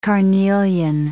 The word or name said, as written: carnelian